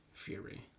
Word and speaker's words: Fieri: Guy, is it Fiore or Fieri? – Fieri.